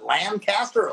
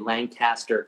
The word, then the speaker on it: Lancaster: Well, I mean, I doubt you guys would have ever played Lancaster, California, but that's how you pronounce it out there.